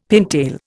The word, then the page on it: pintail: Indian